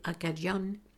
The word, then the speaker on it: Acadian: Les descendants des Acadiens, prononcés Acadian, sont maintenant connus sous le nom de Cajun, prononcé Cajun.